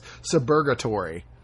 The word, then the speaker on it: Suburgatory: Suburgatory. – Yeah, is that how you say it? – Yeah.